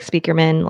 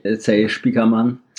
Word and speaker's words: Spiekermann: Also, it's funny that I say Spiekermann, even though it's like a German name. I could say Spiekermann, but that sounds wrong to me because I listen to all the interviews and he always introduces himself in the English pronunciation.